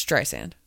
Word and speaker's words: Streisand: How it's Streisand. That's how you say it.